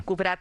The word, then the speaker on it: Kubrat: Sí, Kubrat. – Kubrat. – Con acento en la A, sí.